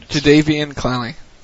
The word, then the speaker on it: Jadeveon Clowney: Jadeveon Clowney.